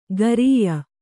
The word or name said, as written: ಖರಿಯ